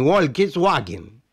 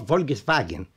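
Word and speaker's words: Volkswagen: وحتى نطق الاسم يختلف عن الشائع عربيا، فحرف W في الألمانية ينطق V، كما في فوكس واجن يكتب W يعني فولكس واجن، لكن يلفظ فولكس فاجن،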